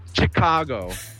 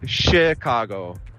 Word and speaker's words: Chicago: Ich habe bemerkt, dass die Deutschen, sie sagen Chicago sehr komisch, also nicht sehr komisch, aber sie sagen immer Chicago, aber ja, tatsächlich. Obwohl normalerweise CH auf Englisch CH ausgesprochen wird, eigentlich ist es Chicago, als ob es SCH wäre.